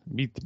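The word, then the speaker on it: Witsel: ¿cómo se pronuncia? Wesley, Wesley o... Bitzel. Bitzel, vale.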